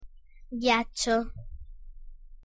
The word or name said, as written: ghiaccio